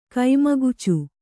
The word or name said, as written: ಗಮ್ಮಕುಚ್ಚು